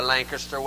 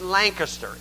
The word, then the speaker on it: Lancaster: the Lancaster way to pronounce the name. It's Lancaster. It's not Lancaster. That's Ohio.